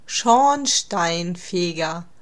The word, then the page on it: Schornsteinfeger: /ˈʃɔʁnʃtaɪnˌfeːɡɐ/